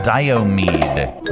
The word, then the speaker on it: Diomede: Diomede.